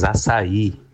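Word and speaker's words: açaí: The pronunciation is acai. Acai. Acai bowl.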